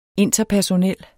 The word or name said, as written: interpersonel